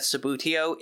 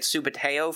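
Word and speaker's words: Subbuteo: In fact, I just learned that Sabutio is the proper pronunciation, whereas my brothers and I have been calling it Subuteo for decades.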